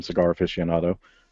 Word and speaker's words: Cigar Aficionado: Another interesting story that I ran across, also from Cigar Aficionado, and I've actually said that right 3 times tonight, which is nothing short of a miracle.